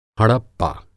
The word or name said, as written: हड़प्पा